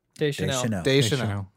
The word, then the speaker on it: Deschanel: Is Zoe Deschanel? – Deschanel.